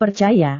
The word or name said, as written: percaya